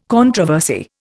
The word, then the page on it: controversy: Indian